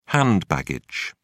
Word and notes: hand baggage: British English

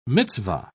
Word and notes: mitzvah: British English

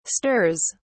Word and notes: stirs: British English

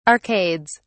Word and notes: arcades: British English

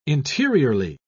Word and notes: interiorly: British English

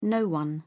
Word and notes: no one: British English

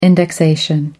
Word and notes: indexation: American English

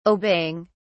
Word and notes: obeying: British English

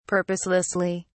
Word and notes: purposelessly: British English

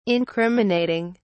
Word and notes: incriminating: British English

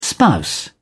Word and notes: spouse: British English

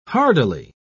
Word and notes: hardily: British English

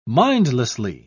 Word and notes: mindlessly: British English

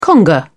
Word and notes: conga: British English

